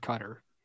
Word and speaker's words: Qatar: Cater, that's how, isn't that how? No, that's how Matt told me to pronounce it last week. Remember? – He didn't. – That's definitely what he said. It was not Qatar. – He said Qatar. – That's what it is.